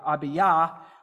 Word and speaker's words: Abijah: And then his other son is, we would say Abijah, but they may pronounce more like Abiyah, which is a combination of the Hebrew word Abib, which would mean father, and then Yahweh.